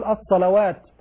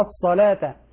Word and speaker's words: الصلاة: وأقيموا الصلاة، بعض الإخوة نبهنا سابقا يقول الصلوات، الواو هنا لا تنطق يا إخوة، الصلاة، الصلاة، الواو لا تنطق. وكذلك بعضهم يقف بالتاء يقول الصلاة، الزكاة، وهذا خطأ. حينما نقف على مثل الصلاة تبدل التاء هاء، نقول الصلاة، الزكاة.